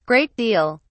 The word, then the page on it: great deal: British English